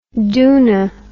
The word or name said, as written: doona